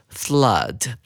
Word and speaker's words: flood: 紧接着，我们来看第三个单词 flood，这是一个可数名词，它指的是洪水。同学们要特别注意到这个单词的发音，这两个 o 呢，念的是 u 的发音。